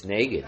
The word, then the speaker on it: Negan: It's pronounced Reagan, so if you take out the R and put an N, it's Negan.